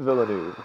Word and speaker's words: Villeneuve: I can never say his name. – My boy, Villeneuve. – Villeneuve, yes.